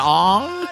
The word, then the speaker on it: Ong: Y'all, she hangs around with that John Ong. Rhymes with Gong.